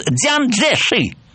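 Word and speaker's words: Цзян Цзеши: Чан Кайши – это гуандунское произношение. А если он приезжает в Пекин, если его называют по его иероглифам, он звучит Дзян Цзэши. Согласитесь, что Чан Кайши и Дзян Цзэши – это разные звучания.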